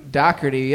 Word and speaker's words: Dougherty: Dougherty, yep. – Yep. D-O-U-G-H. – D-R-T-Y.